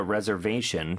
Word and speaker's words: reservation: Reservation. 자, 강세 들리셨죠? 그리고 S 발음은 Z 발음으로 reservation.